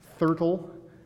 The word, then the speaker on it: Thurtle: Thurtle. It rhymes with turtle, and I don't know if he looked like that or not.